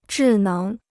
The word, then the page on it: 智能: zhì néng